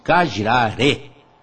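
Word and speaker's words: Kajra Re: Essa chama-se Cajiraré. Senhores, ouviram aí? Eu estou caprichando na pronúncia.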